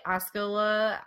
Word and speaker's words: Osceola: Osceola, Iowa, on the night of the murders. He had been arrested for vagrancy there, and the Osceola, or, that's right, you said it right, sheriff recalled putting him on a train to send him away at approximately 11 p.m.